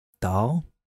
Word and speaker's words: Randahl: R-A-N-D-A-H-L. – Yep. – How's that pronounced? Do you think the D-A-H-L? – Dahl. – Dahl? – Dahl. – Dahl? – Dahl. – Dahl. Dahl. Okay.